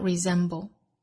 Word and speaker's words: resemble: resemble, cannot 重读在前，尾部的略调 resemble, 同样注意这个词啊，英音发音和美音发音是相同的，但是都要注意两点，一个是字母 s 读浊音，一个是最末尾的舌侧音不能丢 resemble。